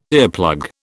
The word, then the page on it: earplug: British English